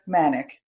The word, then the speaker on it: Manek: I am your host, and very excited to have with me today Dr. Nisha Manek.